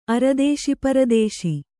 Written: ಅರದೇಶಿಪರದೇಶಿ

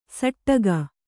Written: ಸಟ್ಟಗ

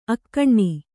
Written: ಅಗ್ನಿ

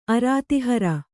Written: ಅರ್ಧಹಾರ

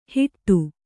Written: ಹಿಟ್ಟು

